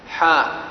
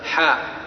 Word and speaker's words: حاء: وإذا نطقتها أيها القارئ كيف تنطقها؟ حاء. قال الإمام مسلم بن الحجاج حدثنا فلان، قال حدثنا فلان حاء، قال حدثنا، قال حدثني فلان، قال حدثنا فلان، وهكذا، هكذا تنطق بها حاء، وهي معناها التحويل.